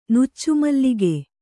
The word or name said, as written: ನುಚ್ಚುಮಲ್ಲಿಗೆ